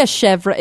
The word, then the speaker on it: chèvre: But I like a chèvre.